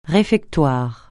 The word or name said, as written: réfectoire